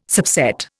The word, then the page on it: subset: Indian